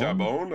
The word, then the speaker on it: Chabon: Chabon. Chabon.